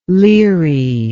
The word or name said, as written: leary